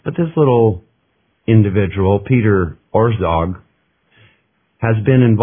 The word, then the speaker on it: Orszag: And now here's how you spell it. It's O-R-S-Z-A-G. So it's O-R-S-Z-A-G. I'm pronouncing it Orszag.